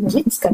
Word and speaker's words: Nowicka: Dann buchstabiere ich meinen Nachnamen und dann sagt sie, ah, Nowicka. Ich sage, nein, Nowitzka ist ein polnischer Name. Ich weiß, dass er polnisch ist, deswegen Nowicka.